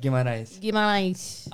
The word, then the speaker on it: Guimarães: Guimarães.